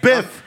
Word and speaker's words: Biff: Biff. I'm Biff.